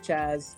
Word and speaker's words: Chaz: No, my name's Chaz, like Jazz, okay. But with a C-H instead of J.